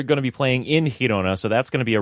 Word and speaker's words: Girona: Girona, am I saying that right? – Girona. – Girona. – There you go.